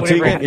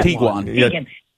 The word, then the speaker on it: Tiguan: Tiguan? – Oh, Tiguan. Yeah, Tiguan.